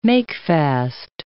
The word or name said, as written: makefast